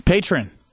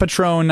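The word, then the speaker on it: Patrón: He says, say it like pay trend. Patron. Patron, there you go. Not patron, not patron, patron. Correct.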